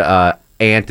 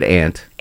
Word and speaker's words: aunt: If you have to do a Pike nursery spot and the word aunt is in there, pronounce it aunt. – Aunt, not aunt.